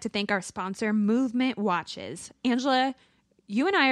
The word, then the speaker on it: MVMT: It's MVMT, though. Just to be clear, it's called Movement. – It's spelled MVMT. – Yeah, it's spelled MVMT. – But you say it Movement.